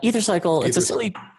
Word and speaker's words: Ethercycle: Is that how it's pronounced? – Ethercycle. When we were building the e-commerce business, it was supposed to be four bike shops. So it was a portmanteau of Ethernet and bicycle.